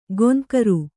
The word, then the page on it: ಗೊಂಕರು: goŋkaru